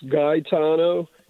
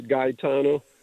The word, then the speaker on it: Gaetano: That's spelled, it is spelled G-A-E, is that correct? T-A-N-O? – Yeah, and I sounds like an E, and the E sounds like an I in Italian, so it's Gaetano.